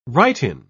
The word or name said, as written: write-in